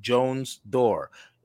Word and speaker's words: Jones-Durr: Her name is Beverly Jones-Durr.